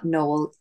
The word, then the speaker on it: Noel: It is Georgina Noel, EFT. My surname Noel is spelled like Christmas, Noel. So yes, Georgina Noel, EFT on Instagram.